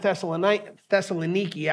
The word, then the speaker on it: Thessaloniki: Well, he went to them in Thessaloniki, actually is how it's pronounced. I was told by a Greek.